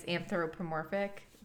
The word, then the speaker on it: anthropomorphic: I feel like it's anthropomorphic.